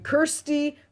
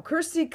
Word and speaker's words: Kersti: Kirsty, Kirsty no, Kersti Kaljulaid. No.